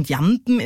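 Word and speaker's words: Jamben: Also bei der korrekten Aussprache von Jampen ist es wichtig, die Betonung auf die zweite Silbe zu legen.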